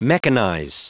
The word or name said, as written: mechanize